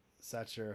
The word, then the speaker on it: Sacher: So we went to the Sacher Hotel. That's not how you pronounce it. It's S-A-C-H-E-R.